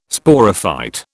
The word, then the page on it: Sporophyte: British English